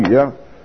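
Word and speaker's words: 茹: 讀作咩啊？讀返如啊。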